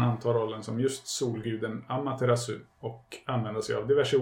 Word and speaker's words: Amaterasu: Amaterasu.